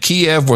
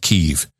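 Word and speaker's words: Kyiv: They said Kiev was the Russian pronunciation during the Soviet era. Ukrainians have always pronounced it Kiev.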